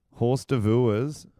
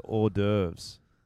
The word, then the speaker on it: hors d'oeuvres: Horse d'oeuvres is actually pronounced hors d'oeuvres.